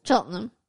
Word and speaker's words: Cheltenham: Is that how you say it? Cheltenham. – I mean, you've added a few more. – Cheltenham. – There you go. – Cheltenham. It's very, like, quick.